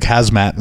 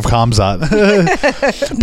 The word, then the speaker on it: Khamzat: And, you know, and now he's saying that he's going to fight Khazmat in the future for the belt, which I said his name wrong. I said it like Khazmat instead of Khamzat.